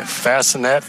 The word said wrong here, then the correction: Fasnacht: Fasnacht. – Fasnacht. Fasnacht.